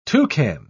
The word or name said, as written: toucan